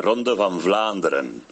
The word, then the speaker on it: Ronde van Vlaanderen: Me vais a perdonar, pero yo que soy flamenco, soy belga, la ronda de Flandes, ¿vale? Se dice de Ronde van Vlaanderen. Nada que ver con lo que estaban diciendo.